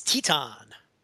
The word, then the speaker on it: Titan: Uh, just another Liger, uh, Ricochet, Rocky Romero, Taiichi, and Titan or Titan. I don't know. I never knew how to pronounce that, but there you go. It's Titan. – Okay. – I used to say Titan.